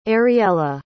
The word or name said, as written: ariela